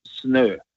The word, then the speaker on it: snø: So, Yeah, that's pronounced as an Ø, so it's Norsk snø.